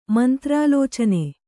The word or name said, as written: ಮಂತ್ರಾಲೋಚನೆ